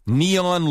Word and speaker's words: neon: Neon lights will give the place a brighter look. – 네, 발음만 주셨어요. 네온 해도 되지만. – Yeah, N-E-O-N, neon.